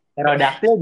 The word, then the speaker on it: pterodaktil: Pterodaktil juga pterodaktil ada P-nya tuh.